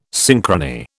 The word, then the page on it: synchrony: British English